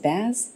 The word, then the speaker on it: Baz: Reuniting with Baz Luhrmann. Yeah. Am I pronouncing that right? Is it Baz? – Baz.